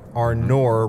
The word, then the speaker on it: Arnor: So when you see Arnor, right? Arnor mm-hmm. rhymes with door.